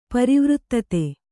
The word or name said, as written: ಪರಿವರ್ಧಿತ